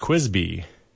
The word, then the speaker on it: KWSB: The community is invited to an open house with KBUT at the KWSB, pronounced Quisby, Quisby Studios, Tuesday, September 15th from 4 to 8 p.m.